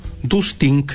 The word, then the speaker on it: Dustink: miproyecto.cl o Dustink, se escribe tal como se lo estoy diciendo con K al final, Dustink.cl.